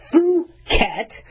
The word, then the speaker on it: Phuket: I know you want to do, you know, short U instead of long U, but it's Phuket, and that's a Yamagata lineage.